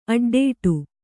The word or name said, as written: ಅಡ್ಡಡಿ